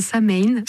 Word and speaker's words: Samhain: Nous, on prononce Samhain.